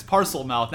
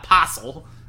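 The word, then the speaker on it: Parselmouth: It's parcel mouth, not possel. There's an R in there.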